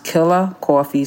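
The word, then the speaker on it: Killah Koffee: It's pronounced Killer Coffee, spelled K-I-L-L-A-H, Killer Coffee, K-O-F-F-E-E.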